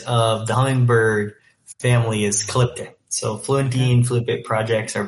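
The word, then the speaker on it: Calyptia: Calyptea. Is that the correct pronunciation of the company as well? – Yeah, Calyptea. You got both. – Yeah. – Calyptea.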